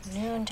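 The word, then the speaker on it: Nuun: Same thing with Noon tablets.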